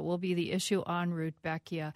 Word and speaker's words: Rudbeckia: Speaking of which, you're going to have to help my pronunciation here. My Rudbeckia? Rudbeckia? – Rudbeckia, yes.